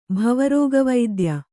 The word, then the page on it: ಭವರೋಗವೈದ್ಯ: kannada